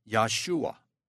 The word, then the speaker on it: Yahshua: In Hebrew, the true name of God is YHWH, pronounced Yahweh, and the true name of Jesus is YHSWH, pronounced Yahshua.